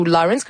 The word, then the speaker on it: Lawrence: Rajoutez les J dans le mix pour vrai, parce que c'est leurs textes sont super beaux, puis la voix de Laurence, ou Lawrence, comme je vous dis, ça s'écrit L-A-W-R-E-N-C-E, est vraiment belle et ça nous charme automatiquement.